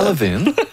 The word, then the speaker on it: Levin: Je pense que c'est Ira Levin. – Levin. – Ouais, je ne sais pas comment on prononce en anglais. Ira Levin. – Ira Levin. – Ouh, ça sonne irrelevant un peu.